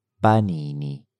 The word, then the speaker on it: panini: Then we had panini. Panini. Panini. Panini. – Interesting fact. Panino just means sandwich in Italian. Panini, ending in the letter I, pronounced E, is the plural.